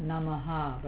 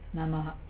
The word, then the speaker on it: Namaha: With a very, very small H, not Namaha, but Namaha. You're just touching the H.